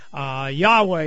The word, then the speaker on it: Yahweh: We used to pronounce it as Jehovah. You've probably heard that before. Yahweh is now what they tell us how the Jews would have actually said it.